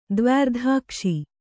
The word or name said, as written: ದ್ವ್ಯರ್ಧಾಕ್ಷಿ